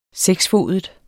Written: seksfodet

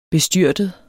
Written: BESTYRTEDE

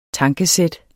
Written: tankesæt

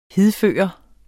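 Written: HIDFØRE

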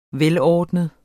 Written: velordnet

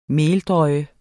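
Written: MELDRØJE

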